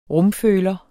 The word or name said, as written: rumføler